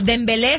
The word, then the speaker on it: Dembélé: Pero, por ejemplo, Dembélé sí tiene acento en la última, ¿eh?